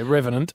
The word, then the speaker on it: Revenant: Well, we're hard to go past the Remnant. How do you say that? Remnant? – Write that down with a... – Yeah, Revenant.